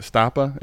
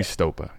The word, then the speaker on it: Stopa: like Stapa. It just it's probably where it came from my brain. But I, now that I know the correct pronunciation, it'll always be Stopa.